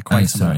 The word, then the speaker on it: Quatermass: Is that how you say it? – Yeah, Quatermass.